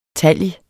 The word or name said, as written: talg